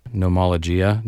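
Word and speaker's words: Gnomologia: In the 1730s, a Thomas Fuller quote in his work, Nomologia, G-N-O-M-O-L-O-G-I-A. I think I said it right. – Yeah, that's good.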